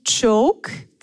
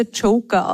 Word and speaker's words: Choke: Also es heißt, okay, es heißt eigentlich Joke, C-H-O-K-E. Oft fälschlicherweise Joker ausgesprochen.